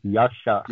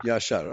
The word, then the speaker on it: Yashar: It is given in the Strong's dictionary as Ashar, but when it is pronounced, it comes with a Y in the front as Yashar. – Yashar, okay.